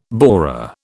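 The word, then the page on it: bourrée: British English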